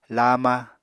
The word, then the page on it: laama: Apatani